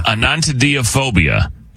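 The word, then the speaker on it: anatidaephobia: Anatidiphobia. – Anantidephobia. – Felt that on my neck. – Yeah, hello. – Anantidephobia. Phobia. – Anantidephobia is what it is, and that's how you say it.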